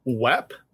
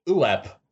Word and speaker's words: WEP: And I think it's pronounced WEP. – WEP? – WEP. Because in the intro, there's a guy that's like, WEP Systems. – Oh, yeah, that's right. You're right. WEP Systems. Not UEP Systems.